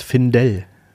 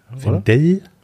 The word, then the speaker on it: Finndell: Ich glaube, er heißt Findel. – Findel, ja. muss die Betonung dann noch nach hinten packen. Aber der ist jetzt in Deutschland, also heißt er Findel.